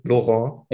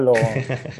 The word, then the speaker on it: Laurent: Laurent oder Laurent, wie möchte er ausgesprochen werden? Laurent. – Ich hoffe Laurent. Okay. Ich hoffe das.